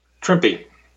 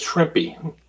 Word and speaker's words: Trimpe: What's the authoritative pronunciation on Herb's last name? – Trimpe. – That's what I thought. That's the way I've always said it, going back 40 years, but, you know. – Well, as he said, it rhymes with Trimpe.